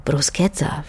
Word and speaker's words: Bruschetta: Und ehe man zudem solche Sachen wie das mit dem C und dem H, das wie ein K ausgesprochen wird im Italienischen, wie eben in Krucki oder Bruschetta verstanden hat, kann es ja auch mal ein paar graue Haare lang dauern.